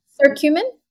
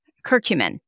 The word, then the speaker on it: curcumin: curcumin. Is that how you say it? The cumin? No, it's not cumin. It's um, curcumin. Yeah, curcumin. Turmeric.